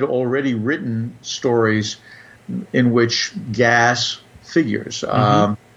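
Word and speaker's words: gaz: The word gas, which is pronounced, you pronounce the S, unlike some words that end in S in French.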